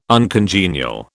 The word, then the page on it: uncongenial: British English